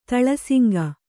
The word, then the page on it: ತಳಸುಂಕ: taḷa suŋka